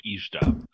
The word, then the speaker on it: Ishtab: Yeah, like, whenever until I die, if ever I hear purple pants, I will think of Ishtab. – Ishtab, baby. – It's like MC Hammer, that's off the table. It's Ishtab. – So Dougal, it's Sultan Ishtab Bej Fedor. That's how you pronounce the whole name.